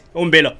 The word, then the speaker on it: Umbilo: Two houses were allegedly torched in Umbilo. And the way I said it is so English. Umbilo. Umbilo, yes. Did I? Hey, Baba, we're doing it. Umbilo, yes. Said it right.